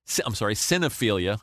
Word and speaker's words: Cinephilia: Cinemaphilia. Cinephilia. I added a syllable like Homer there.